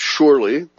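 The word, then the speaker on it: Shurley: Shirley, spelled with a U, S-H-U-R-L-E-Y, I'll pronounce it Shirley, responded that his gut feeling would be yes, he would be capable of that.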